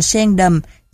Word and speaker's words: sen đầm: Viết là sandam. Âm này đã được Việt hóa thành sen đầm cho dễ đọc, dễ viết, dễ nhớ.